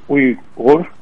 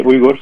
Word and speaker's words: Uyghur: To answer your question, first of all, we are Uyghur. It's pronounced Uyghur, but okay. – People can say Uyghur since there is no V in English.